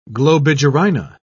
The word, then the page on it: globigerina: British English